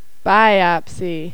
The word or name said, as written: biopsy